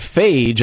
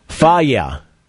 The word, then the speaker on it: Fage: Greek yogurt brand, F-A-G-E. That is dangerously close to something that's not yogurt. F-A-G-E. It's not phage, I wouldn't think. How would you say it? – I don't want to touch that one. – Oh, come on. There's nothing wrong with that. Faya. – Faya. – Faya. So the Greek yogurt, F-A-G-E.